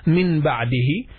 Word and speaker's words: من بعده: always when من comes after it بعد we pronounce the noon as a meme